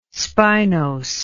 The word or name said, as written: spinose